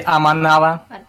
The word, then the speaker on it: Аманава: На связи Аманава. Ударение было правильно.